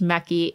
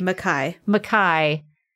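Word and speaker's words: Mekhi: Somebody said that apparently Tracy said it's not pronounced Mecky. It's pronounced like Mackay. It's like Mackay Pfeiffer.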